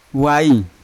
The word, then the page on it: wail: Yawa